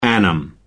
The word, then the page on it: annum: American English